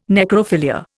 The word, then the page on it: necrophilia: Indian